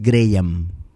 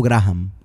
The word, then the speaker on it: Graham: Señor presidente, la pronunciación es Graham. Alexander Graham Bell. No Graham.